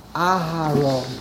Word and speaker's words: Aharon: Aharon, you pronounce the hey very quickly. So when you pronounce it very quickly, it almost sounds like a shvo. Aharon.